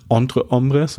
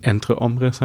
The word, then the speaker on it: Entre Hombres: Ich habe es eine Zeit lang immer falsch ausgesprochen. Dank Julia Weigel weiß ich jetzt, dass es nicht Entre Hombres, sondern Entre Hombres heißt, was mir auch sehr geholfen hat bei meinem Gesprächspartner, den ich dazu interviewt habe.